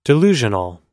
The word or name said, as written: delusional